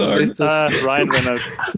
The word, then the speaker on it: Ryan Reynolds: Der Rain Reynolds? – Ryan Reynolds. Um Gottes Willen.